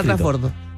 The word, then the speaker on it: transbordo: No, mañana transbordo. – Mañana transbordo, sí, porque... – ¿Es transbordo o trasbordo? – Transbordo. – Transbordo.